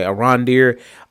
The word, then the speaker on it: Arondir: is that how you pronounce his name? The elf with the bow and arrow, Andron? – Arondir, I believe. – Okay, Arondir.